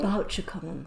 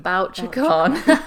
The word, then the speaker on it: Bouchercon: Yeah, so the Malice Domestic Conference in Maryland, and the, I'm not sure how you pronounce this, Bucheron, is that right? – Oh, Bouchercon. – Bouchercon. Yes.